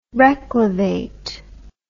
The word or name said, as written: reclivate